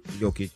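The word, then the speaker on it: Jokić: Team LeBron, first round starters, Giannis Antetokounmpo, Steph Curry, Luka Doncic, and I can't say this right, Nikola Jokic. – Jokic.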